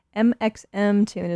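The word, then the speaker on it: mxmtoon: Coming up, we got a new track from Mixum Tune. Actually, I think I just read that it's MXM Tune is the pronunciation, and I've been doing it wrong this whole time.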